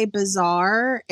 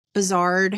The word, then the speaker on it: bizarre: Uh, but she would say things like she loves to say bizarre, except there's a D on the end of it. Bizarred.